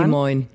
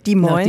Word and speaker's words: Des Moines: Hilf mir nochmal schnell bei der Aussprache. Nach Dis, Dis, Dis Moines. Des Moines. Des Moines.